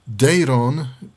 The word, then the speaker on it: Dayron: Urządzenie, o którym mówię, nazywa się Dayron.